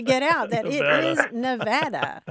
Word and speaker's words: Nevada: My story is even worse. I grew up in Missouri, and there's a town that's spelled the same, but it is pronounced Nevada. So for me, the state was Nevada for a very long time because that's how you pronounce the town in Missouri.